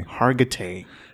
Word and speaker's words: Hargitay: I never know how to say her name. – I think that's how you say it. Mariska Hargitay. – Hargitay.